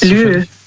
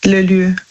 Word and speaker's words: lieu: Lieu. – Lü? – Also Lü, das schreibt man, also L-I, E und dann U. Lü.